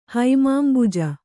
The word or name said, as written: ಹೈಮಾಂಬುಜ